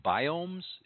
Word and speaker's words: biomes: I looked at some of her stuff, and she does these things called, or she did a lot of pictures on biomes. Is that how you pronounce that? Do you know? B-I-O-M-E? – Yes.